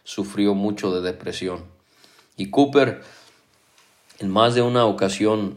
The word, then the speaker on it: Cowper: William Cooper, se escribe su apellido C-O-W-P-E-R, William Cooper fue un escritor inglés de más de 60 himnos.